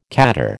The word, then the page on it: khaddar: American English